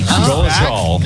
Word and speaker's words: Dolezal: Dolezal.